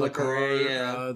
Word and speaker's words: Le Carré: Le Carré.